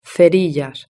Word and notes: cerillas: Spain Spanish

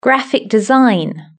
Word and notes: graphic design: British English

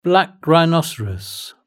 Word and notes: black rhinoceros: British English

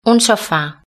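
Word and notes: un sofa: Spanish (Spain)

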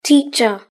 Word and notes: teacher: British English

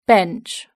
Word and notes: bench: British English

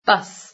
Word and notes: bus: British English

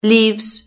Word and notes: leaves: British English

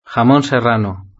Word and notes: jamon serrano: Spain Spanish

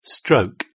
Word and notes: stroke: British English

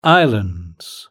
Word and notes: islands: British English

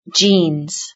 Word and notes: jeans: British English

